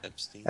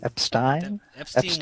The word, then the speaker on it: Epstein: And, you know, learned a lot about Brian Epstein. – Epstein. – Epstein was his preferred pronunciation.